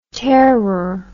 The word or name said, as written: tearer